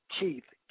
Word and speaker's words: Keith: Keith, K E I T H, first name.